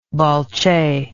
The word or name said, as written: balche